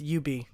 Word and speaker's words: Ubisoft: It's Ubi. Because I remember because, like, 3 years ago, they did a stupid little Shakespeare Ubi. Or you not be. Ubi, surely. Yeah, that stupid little skit they did totally stuck with me.